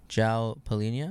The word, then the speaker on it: João Palhinha: João Palhinha.